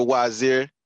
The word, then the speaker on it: Wazeer: Man, okay, yeah, my name is Wazir, better known as Trucker Wazir as of lately. Y'all can follow me on TikTok at Trucker Wazir. Trucker spelled like trucker. Wazir spelled W-A-Z-E-E-R.